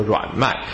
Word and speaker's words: 濡脉: 如果是燥邪跟湿邪呢，就是急脉跟濡脉啊，濡脉也可以念作软脉。